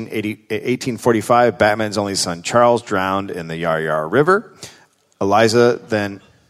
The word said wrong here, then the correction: Yarra Yarra: Oh, is that not how you say it? – Hang on, what did you just call it? – Yarra Yarra? – The Yarra Yarra River.